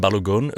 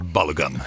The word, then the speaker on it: Balogun: Anledningen, FIFA lät den amerikanska stjärnan Folarin Balogun, uttalar man det så? – Ja, jag säger så, men jag tror att amerikaner kan säga Balogun.